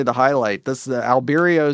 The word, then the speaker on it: Albireo: It may be Albireo or Albireo.